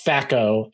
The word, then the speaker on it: THAC0: Yeah, it's THAC0. So it's THACO and it's chance. THACO. – Okay. – Chance to hit armor class 0. – What is T in there? Why is T in there? – The C is silent.